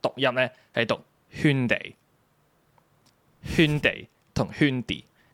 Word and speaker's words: Hyundai: 然之后咧，跟住就有个好靓嘅女士走出嚟，同佢讲个喊大其实系读圈地。然之后佢哦圈地，然之后就哦就系、是、个正确读音就系圈地啦，就系、是、咁样。